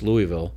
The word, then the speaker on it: Louisville: You got in the first one, you got South Carolina against Louisville. Pronounce it like you got your mouth full of food.